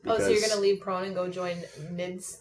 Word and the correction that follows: Nids: Nids. like lids. Lids. Nids. nids.